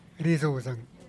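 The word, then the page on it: rizuuzang: Teotitlán del Valle Zapotec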